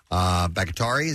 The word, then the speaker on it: Bakhtiari: The pair attended Rodgers' Green Bay Packers teammate David Bakhtiari. Is that how you say his name? – Sure.